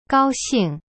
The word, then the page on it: 高兴: gao xing